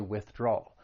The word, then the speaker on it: withdrawal: It has three syllables. The stress is on that second syllable, that draw. But it's that third syllable that I think people have trouble with, that dark L. So you're going to stress that second syllable and move right into that dark L, which has that ull sound. Withdrawal, drawal, withdrawal.